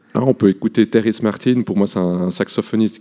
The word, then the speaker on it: Terrace Martin: Bon, écoute, je te propose soit Terrace Martin, c'est comme ça qu'on prononce, ouais.